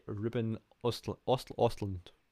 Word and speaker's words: Östlund: Tar, written by Todd Field, and Triangle of Sadness, written by Ruben Östlund.